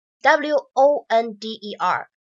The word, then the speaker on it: wonder: 我们呢首先来看一下第一个单词 w o n d e r，它呢读作 wonder，wonder。而第二个单词呢，w a n d e r，它读成是 wonder，wonder，对吧？